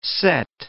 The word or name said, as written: set